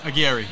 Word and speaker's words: Aguirre: Aguirre, yeah.